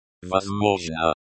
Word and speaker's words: Возможно: Возможно. Emphasize the first syllable.